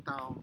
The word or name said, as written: tau